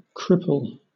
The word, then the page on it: cripple: British English